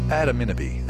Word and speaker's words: Adaminaby: Adaminaby, that's how you say it.